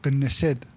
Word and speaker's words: Knesset: Para renovar los 120 escaños de la Knesset, creo que se llama. – Con K, Knesset.